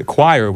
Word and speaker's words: choir: The choir was very good. That's the right pronunciation. Choir.